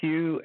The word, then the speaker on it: Hugh: Yes, my name is Hugh, H-U-G-H. The last name is Trawlson. It's spelled T like Tom, R-A-U-L-S-E-N like Nancy.